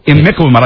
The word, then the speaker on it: Imiquimod: Imiquat. No, that's not right. Imiquimod. I finally got it.